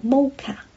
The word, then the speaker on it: MOCA: 这个华人博物馆的全称叫做 Museum of Chinese in America，就是 MM 就是 Museum，O 就是 of Chinese，那就用了个 C in America，那就用了个 A，就是 MOCA， MOCA 是缩写，发音叫做 MOCA，地址是 215 Century Street New York NY，邮编是10013，咁佢开放嘅时间系礼拜六、礼拜日、礼拜四同埋礼拜五，即系呢啲期呢、這个可能系疫情期间啊吓。